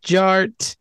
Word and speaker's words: Jart: DJ Art, with two T's for a double dose of that tink tink. The D is silent, so it's just Jart.